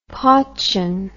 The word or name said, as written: portune